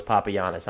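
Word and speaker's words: Papagiannis: And yet you waived Yorgos Papayannis, and I'm looking at his pronunciation like phonetically, and it looks like it's Yorgos Papayannis on Basketball Reference.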